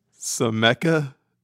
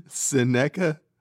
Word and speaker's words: Seneca: Sameca. – Huh? – Seneca.